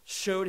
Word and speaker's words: shewed: By the way, it says here that Jesus showed himself. That word S in the King James, it's S-H-E-W-E-D. Which my kids in Bible class always pronounce shewed. Showed, it's the word manifest, to make known.